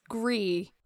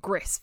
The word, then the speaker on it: Gris: I think it's Gree, but I've been calling it Gris for years until someone went, have you played Gree? And then I was like, is that how you say it? – Hyacinth Bouquet kind of nonsense.